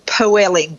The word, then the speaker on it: poeling: It's this ridiculous word that is a principle of cooking that is called poeling. – All right. – P-O-E-L-I-N-G.